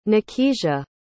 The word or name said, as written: nakisia